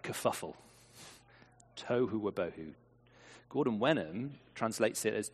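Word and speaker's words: tohu wabohu (תֹהוּ וָבֹהוּ): Form and void in Hebrew, being the great Hebrew scholar I am, it means pronounced apparently tohu wabohu. Tohu wabohu.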